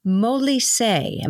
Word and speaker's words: Molise: In fact, I didn't remember ever hearing about this place, Molise. Am I saying that right? – Yes. The